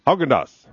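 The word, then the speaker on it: Häagen-Dazs: Haagen-Dazs. Did I say that right? – Haagen-Dazs.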